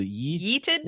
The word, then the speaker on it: yeeted: Yeeted them with a yeeted? – Well, I mean, you said it correctly. That's how he pronounced it when he in a message. He said that's how you say it, but I don't get it.